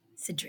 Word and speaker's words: Cidre: But it's spelled like Sidre. It could be Sidre. It looks like cider as in like apple cider, but with an R-E instead of an E-R, but... – Cidre.